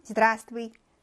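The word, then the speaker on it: 지드라스트위 (здравствуйте): 그다음 안녕 지드라스트위 그래서 맨 앞에 나오는 자음이 묵음입니다. 심지어 이 아이는 자음이 4개가 연달아 사이좋게 나오고 있는데 맨 앞에 있는 아이가 발음이 안 나는 걸 양보했어요. 그래서 자음이 3개가 발음 나고 맨 앞에 있는 외가 발음이 나지 않습니다.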